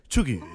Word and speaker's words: cheugy: The proper pronunciation, guys, is Cheugy. – Cheugy. – You must say it very fast.